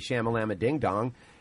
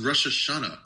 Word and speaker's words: Rosh Hashanah: I hit up a few of my Jewish friends, told them happy shamalama ding dong. And that's, then he's like, yeah, you're mispronouncing it. He got all butthurt. – Dude. – Butthurt. It's just, it's pronounced Rosh Hashanah.